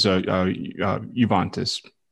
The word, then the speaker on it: Juventus: Okay, we're going to check in with Javantis. – Okay, and pronunciation is Juventus.